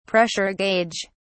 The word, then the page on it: pressure gauge: British English